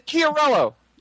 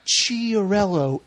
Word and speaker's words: Chiarello: Michael Chiarello. – Yeah, that's it. Michael Chiarello. Thank you. Yes. Michael Chiarello. – He is kind of an asshole. – Because remember, he was like, it's not Chiarello. It's Chiarello.